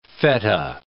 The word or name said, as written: feta